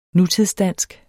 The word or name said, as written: nutidsdansk